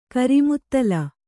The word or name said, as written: ಕರಿಮುತ್ತಲ